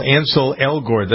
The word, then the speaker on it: Ansel Elgort: These two people, Ansel Elgort, that's how you pronounce his name, right? – Mm-hmm.